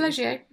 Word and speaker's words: Taleggio: Now, there's a rind to Taleggio. Am I saying it right? The name of the cheese? – Yeah, Taleggio. Yeah, you did.